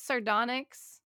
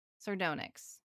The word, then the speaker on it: sardonyx: Well, because it's like onyx, but with a sard. Sardonyx.